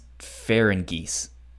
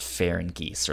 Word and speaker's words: Ferengis: I think it's pronounced Ferengis. Okay. – If I remember from the anime, I believe it's pronounced Ferengis or like Ferengis.